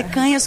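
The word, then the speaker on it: Cañas: Canhas, picanha, o cara nunca mais esquece, mas é Canhas com N com tio, e as redes são Ana, _ Canas.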